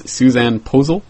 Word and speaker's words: Susanne Posel: It is Susanne Posel? – You got it right.